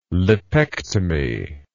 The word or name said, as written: lipectomy